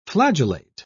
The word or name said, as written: flagellate